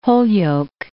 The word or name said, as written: Holyoke